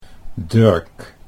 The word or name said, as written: Durk